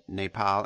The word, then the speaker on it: Nepal: This Trump called Nepal and Bhutan nipple and button in intelligence briefing.